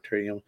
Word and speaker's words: Toriyama: Yeah, so but yeah, you know, just we forgot to mention him last week, but rest in peace, Akira Toriyama. Did I say it right? Toriyama. – Yeah, Kiratoriyama.